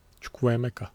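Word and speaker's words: Chukwuemeka: Danso, Vanner ve Çukwu Emeka. Dün nasıl okunuyordu o? O şekilde öyle.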